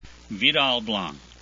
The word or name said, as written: vidal